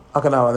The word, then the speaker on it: Okinawa: Then he got posted to, I hope I'm pronouncing this right, Okinawa in Japan. – Okinawa, there we are, okay, in Japan.